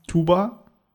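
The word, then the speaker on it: Tuğba: Weißt du, wo man es krass merkt, ist halt, äh, es gibt einen türkischen Namen, einen weiblichen Namen, Tuba, und den schreibt man Tukba.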